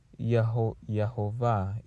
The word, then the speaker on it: Yehovah: Strong's H3068, Yehovah. – Yehovah is how it is pronounced in Hebrew. I had to listen to it first, sorry. Yehovah, right? Almost like Jehovah. Yeah, I think that's what it's called, Jehovah.